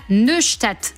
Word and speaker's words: Neustadt: le quartier de la Neustadt. Je suppose que ça se prononce. – C'est pas trop mal, ça va.